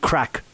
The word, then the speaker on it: craic: Like, it's called That's Good Crack podcast. And for anybody listening from around the world who doesn't know what crack means in the Irish context, it's spelled C-R-A-I-C and it means to have a lot of fun, right?